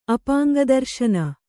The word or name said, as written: ಅಪಾಂಗದರ್ಶನ